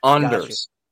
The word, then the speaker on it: Anders: Well, I'm trying not to get us demonetized again, but it's a very pronounced on. Anders. It's Anders.